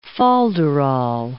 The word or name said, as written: folderol